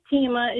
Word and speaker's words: Teima: Hi, my name is Tima. It's spelled T-E-I-M-A. And I absolutely love my name. My mom, we all have unique names. But all through school, my teachers always mispronounced my name. Taima, Tiima, Selma.